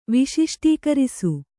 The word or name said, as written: ವಿಶಿಷ್ಟೀಕರಿಸು